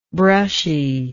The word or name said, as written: brushiness